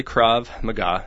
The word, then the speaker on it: Krav Maga: it's Krav Maga, is that how it's pronounced? – Yeah, it's called Israeli Krav Maga, and what that means is contact combat.